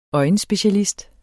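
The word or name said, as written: øjenspecialist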